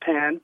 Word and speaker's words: pain: In French, their word for bread is pan, mm-hmm. which is pronounced pan, but it's spelled P-A-I-N, which to us is pain.